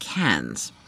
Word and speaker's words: Cairns: He's just come back from Australia to the Australian Tourism Exchange in what I used to pronounce as Cairns, but I believe you pronounce it Cairns.